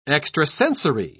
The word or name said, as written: extrasensory